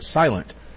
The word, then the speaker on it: SLNT: SLNT. It's pronounced silent.